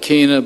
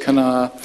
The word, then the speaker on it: Cana: That's how Scott Ashley pronounced it. I always called it Cana, but he pronounces it Cana, as well as Naphtali. I never pronounced it that way. I always called it Naphtali, but he says Naphtali.